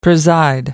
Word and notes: preside: British English

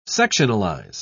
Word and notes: sectionalize: British English

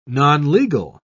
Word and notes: nonlegal: British English

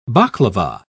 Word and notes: baklava: British English

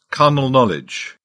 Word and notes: carnal knowledge: British English

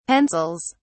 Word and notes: pencils: British English

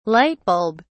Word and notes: lightbulb: British English

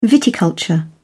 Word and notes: viticulture: British English

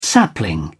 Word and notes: sapling: British English

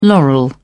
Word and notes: laurel: British English

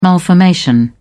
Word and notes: malformation: British English

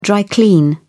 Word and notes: dry clean: British English

